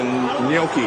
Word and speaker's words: gnocchi: I want the gnocchi. How do you say it, gnocchi? – Gnocchi.